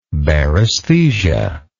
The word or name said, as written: baresthesia